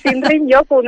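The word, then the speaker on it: Shinrin-yoku: Su nombre japonés es Shinrin Yoku, que seguramente lo he dicho fatal. – No, está muy bien, lo has pronunciado muy bien. Shinrin Yoku, no te creas que yo tampoco lo pronuncio mucho mejor.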